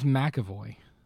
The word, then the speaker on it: McAvoy: James McAvery. Professor X. That was him. Yep. – James McAvoy.